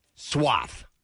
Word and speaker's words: Swarthmore: I'm going to blame the colonials Swarthmoreans for taking the R out of the pronunciation. – Okay. Swarthmore. – Swarthmore. – Swath. – Yeah. – Swath. – All right. – Just forget the R's in there.